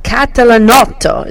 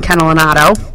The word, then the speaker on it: Catalanotto: Frank Catalanotto is how you say that in Italian, but people call him Frank Catalanotto.